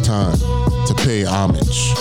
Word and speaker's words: Homage: I had to stare at the mirror all day, point to myself, and look and say, Homage, Homage, Homage.